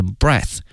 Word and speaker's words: breath: 同时啊，呼吸这个词因为呢它的词性是名词，因此它的发音是 breath，而它的动词呢是 breathe， breathe。